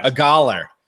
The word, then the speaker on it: Agholor: Anyway, Nelson, how do you pronounce it? Aguilar? – Sure, it's got to be that. – Agholor. Nelson Agholor.